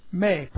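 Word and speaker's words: may (μή): That's M-E. May, pronounced may. It's a long E.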